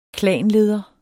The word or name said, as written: klanleder